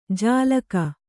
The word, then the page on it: ಜಾಲಕ: Kannada